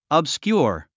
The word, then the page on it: obscure: əbskjúə r; オブスキュア